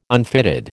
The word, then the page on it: unfitted: American English